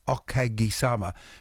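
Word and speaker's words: Okagi-sama (お陰様): Because in Japanese, all the syllables have equal weight. Okagi-sama. Whereas in English, it's the third last syllable that carries the weight.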